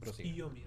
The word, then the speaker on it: Tsukiyomi: Y Sukiyomi era dios de la luna. – Tsukiyomi, recuerda que la U no se pronuncia.